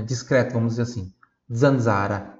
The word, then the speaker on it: zanzara: Zeta, zaino, zero, zanzara. Tanto no primeiro quanto no segundo Z aqui, pronuncia dolce, zanzara, ó, oh, como se fosse oh.